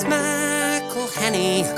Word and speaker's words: McElhenney: If there was doubt, now there won't be any. It's McElhenney.